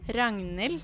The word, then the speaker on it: Ragnhild: My name is Ragnhild. Yeah, it's a very difficult Norwegian name. – How, just say it again. – Ragnhild. – How do you spell that? – R-A-G-N-H-I-L-D.